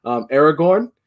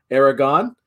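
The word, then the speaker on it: Aragón: Artist is I always want to say his name is Alejandro Aragorn, but it's Alejandro Aragon.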